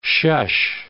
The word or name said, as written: shush